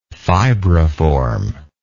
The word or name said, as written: fibriform